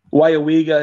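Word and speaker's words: Weyauwega: We had talked about maybe adding a region class this year when Wyawega had dumped Light Pro. – Where? – Thought maybe there was an opportunity to. – He said it right, Charles. – He did say it right.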